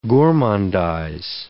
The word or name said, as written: gourmandize